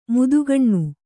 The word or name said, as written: ಮೋದೀಖಾನೆ